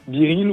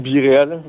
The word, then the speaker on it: Be Real: Alors l'association Biril ou Biréal, les gens choisissent la prononciation,